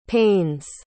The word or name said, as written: panes